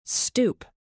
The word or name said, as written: stoop